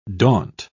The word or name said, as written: daunt